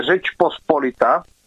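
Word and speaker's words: Rzeczpospolita: No, je to spíše to, zaprvé tam opravím tvoji výslovnost, to, co zvyslo, tak to je Řeč Pospolita.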